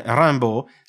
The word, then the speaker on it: Rimbaud: Da persona che parlava inglese e americano, la pronuncia di questo autore sembrò a Morrell molto strana, perché nel nome Rimbaud si applicano diverse regole di pronuncia tipicamente francesi. La IM, che diventa una EM nasale molto simile al suono AM, e il dittongo AU che si pronuncia O, mentre la D finale sparisce del tutto.